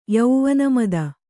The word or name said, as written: ಯೌವನಮದ